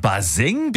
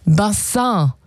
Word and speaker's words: Bassin: Ja, es kommt eigentlich aus dem Französischen. Bassin. Und bedeutet Becken oder Wasserbecken. – Das kam so, das kam so im 18., 19. Jahrhundert hier rüber nach Deutschland. Wie viele andere Begriffe aus dem Französischen damals. Und naja, die Aussprache, die hat ein bisschen gehapert. Also statt Bassin wurde es irgendwann Baseng.